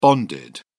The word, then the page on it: bonded: British English